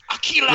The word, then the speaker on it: Akira: Sorry, no, you said it wrong. It's Akira!